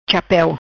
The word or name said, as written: Chapeu